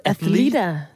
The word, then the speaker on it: Athleta: Athleta. – Athleta.